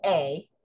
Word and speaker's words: Ai: And chapter 7 starts where Israel is defeated at, now, the name is called A, and it's A, capital A, and a little I. I say AI, but the word is actually A. It's pronounced A, but I'm going to say AI, but the word is pronounced A.